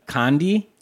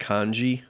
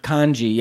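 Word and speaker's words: Khondji: And so his director of photography on this was Darius Kandji. – No, I think it's pronounced Kandji. – Kandji, yeah.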